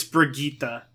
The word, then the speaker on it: Brigitte: I don't like Bridget. She hurts me, but it's Brigitte. That's how you pronounce it.